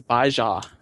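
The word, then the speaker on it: Bai Ze: The Baijia. That's how you'd pronounce it.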